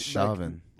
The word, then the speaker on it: Chauvin: I thought it was Chauvin.